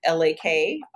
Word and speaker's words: Lakrisha: My name is spelled Lakrisha.